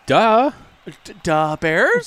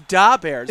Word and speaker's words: Da Bears: first of all, it's Da Bears. It's not Duh Bears.